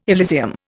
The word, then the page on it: Elysium: Indian